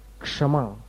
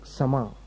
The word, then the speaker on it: क्षमा: गुजराती लोग कई बार क्षमा, क्षमा के बदले समा या ऐसा कुछ बोलते हैं उच्चारण, ऐसा कुछ करते हैं वो, रक्षा बेन या दक्षा बेन ऐसा कुछ बोलते हैं, पता नहीं। तो क्ष, क्ष का जो उच्चारण है वो शुद्ध, ये सब उच्चारण संबंधी सारे जो नियम है, छंद पाठ जो है वो सब शब्दाचार में आते हैं।